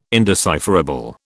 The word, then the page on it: indecipherable: British English